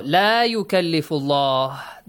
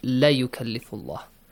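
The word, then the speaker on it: La yukallifullah (لا يكلف الله): Do not, be careful, don't pronounce it la yukallifullah. Yep. La yukallifullah, because the word la is kind of confusing for some of the students. If you say la yukallifullah, it means for sure Allah what? Tasks any soul beyond the capacity. This is wrong, yes? This is not what the ayah says.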